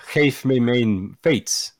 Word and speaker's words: geef me mijn fiets: Or if I can try and pronounce this in Dutch, geef me mijn fiets. – Nailed it. – Yeah. Yeah, I can confirm that's correct.